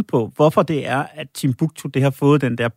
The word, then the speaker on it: Timbuktu: Og mens butleren øh, sendes til Timbuktu, er det sådan, man udtaler det land? Yes.